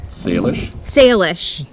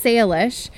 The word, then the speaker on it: Salish: Samish Sea. – Salish. – There it is. Salish.